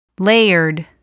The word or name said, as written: layered